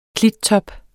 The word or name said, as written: klittop